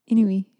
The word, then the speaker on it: Inuit: Is that how you pronounce that? Inuit? – Yeah. Inuit.